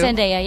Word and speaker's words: Zendaya: Zendaya, yep.